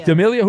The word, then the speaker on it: D'Amelio: Dixie D'Amelio. Is that, I'm saying that right? Yeah, 25. Is that an actress? – D'Amelio.